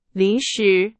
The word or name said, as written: 临时